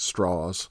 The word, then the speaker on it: Strauss: It's spelled like Strauss, but he pronounces it Strauss.